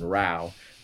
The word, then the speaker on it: Rowe: David Nelson Rowe, R O W E.